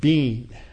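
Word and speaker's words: bean (בין): Now, the word discernment in the Hebrew, that's translated discernment from the Hebrew, is the word bean in Hebrew, pronounced like our word B-E-A-N.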